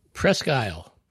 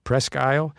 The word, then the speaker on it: Presque Isle: Presque Isle. – Presque Isle. All right. Well, they failed to see the runway there in 2019 And the result was, well, not ideal. – Now, Presque Isle